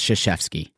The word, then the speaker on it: Krzyzewski: And yes, we understand it's confusing because his last name begins with a K, but it's pronounced Krzyzewski.